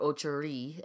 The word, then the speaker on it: Ocho Rios: So if you take a cruise to Jamaica, you're going to stop in Ocho Rios, or Ocho Rios, as the locals say.